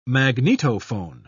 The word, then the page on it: magnetophone: British English